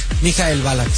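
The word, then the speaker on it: Michael Ballack: ¿Cómo se dice bien? ¿Mikhail Balak? – Mikhail Balak, sí.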